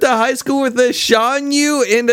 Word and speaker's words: Shan Yu: You nailed it the first time. It's Shan Yu.